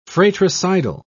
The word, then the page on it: fratricidal: British English